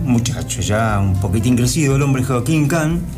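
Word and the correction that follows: Kühn: es un, o Kuhn, como se pronuncia, es un apellido alemán, Kuhn, porque tiene la diéresis sobre la U, ¿no?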